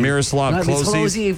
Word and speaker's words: Miroslav Klose: Miroslav Klose for career scoring at the World Cup.